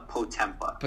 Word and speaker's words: Potempa: It's uh, Potempa.